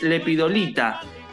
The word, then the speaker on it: Lepidolita: El tema es que el libro es, y corregime Cintia si eh, pronuncio mal el acento, es Lepidolita, eh, entiendo que es así.